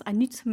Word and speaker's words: Hann'ut: Je suis tombée sur votre magasin à Anu. On va parler d'Anu majeur. C'est ton magasin. – Oui, petite précision, ça se prononce Anut majeur.